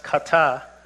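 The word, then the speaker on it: kata (חטא): So up there I have the Hebrew word, which is pronounced kata.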